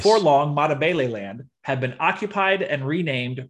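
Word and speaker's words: Matabeleland: This is Matabele land. – Matabele land.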